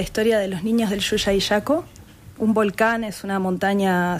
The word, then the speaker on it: Llullaillaco: Sería Llullaillaco. – Llullaillaco.